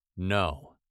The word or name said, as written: no